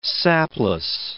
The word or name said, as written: sapless